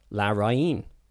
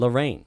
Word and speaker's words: Larraín: Pablo Larraín's last name is pronounced Larraín rather than Lorraine, which is how we've been pronouncing it like Lorraine Kelly. – Yeah. – Larraín. But it's like Larraín. – Larraín. – Larraín. – Pablo Larraín. – Because there's an accent on the I.